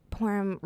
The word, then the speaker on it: Purim: So just recently, I think it was Purim. Is that Purim. – How you say it? – Mm-hmm.